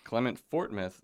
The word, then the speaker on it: Clement Fortmouth: Clement Fartmouth, that right? – No, that's not it. It's Fortmouth. Fortmouth. Clement Fortmouth. – My apologies. Clement Fortmouth, the host of Valid Points, is with us.